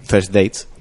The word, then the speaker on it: First Dates: A ver, eh, anoche salí, anoche sábado por la noche emitieron el programa de First Dates, lo he dicho bien.